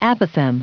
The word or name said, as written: apothegm